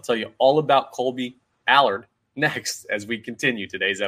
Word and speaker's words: Allard: It's a minor move, but who is Kolby Allard? I got to learn how to say his name right. I believe it's Allard like Mallard.